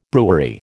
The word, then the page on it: brewery: American English